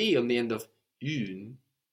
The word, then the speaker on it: une: For feminine nouns, like la baguette, la bicyclette, la situation, la porte, we have une.